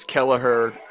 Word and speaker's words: Kelleher: It's Kelleher, about as Irish as you can possibly get.